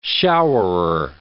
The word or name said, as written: showerer